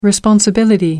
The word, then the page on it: responsibility: American English